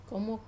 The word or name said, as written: komok